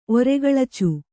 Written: ಒರೆಗಳಚು

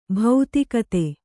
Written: ಭೌತಿಕತೆ

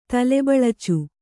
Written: ತಲೆಬಳಚು

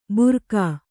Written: ಭೂರ್ಗ

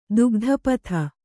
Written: ದುಗ್ಧಪಥ

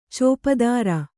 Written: ಚೋಪುದಾರ

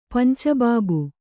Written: ಪಂಚಬಾಬು

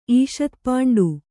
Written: ಈಷತ್ಪಾಂಡು

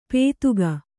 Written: ಪೇತುಗ

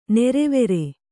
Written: ನೀರುವರಿ

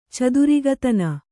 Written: ಚದುರಿಗತನ

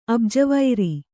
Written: ಅಬ್ಜವೈರಿ

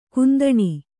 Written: ಕುಂದಣಿ

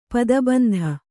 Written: ಪದಬಂಧ

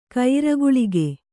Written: ಕಯ್ರಗುಳಿಗೆ

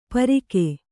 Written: ಪಾರಕ

